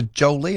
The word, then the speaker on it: Joli: It's called a Jolly and it's spelled J-O-L-I, worth $1 billion apparently every year.